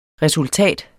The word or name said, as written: resultat